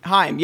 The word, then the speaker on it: Haim: AIM? I don't know how you pronounce that. H-A-I-M? Haim, yeah. – Haim. – Haim. You gotta think of the Jewish way.